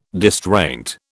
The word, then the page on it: distraint: British English